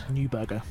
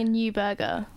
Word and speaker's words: Neuberger: I mean, I think it's interesting that he did, I mean, I can't pronounce the name, Lord Neuberger. – Thank you. – Like, think of getting a Neuberger. – Neuberger.